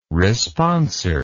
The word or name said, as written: responsor